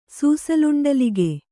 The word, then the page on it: ಸೂಸಲುಂಡಲಿಗೆ: Kannada